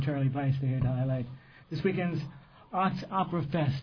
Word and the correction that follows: Operatoberfest: Did I say that right? – Operatoberfest. Takes a little while to get your tongue around the title, that's for sure.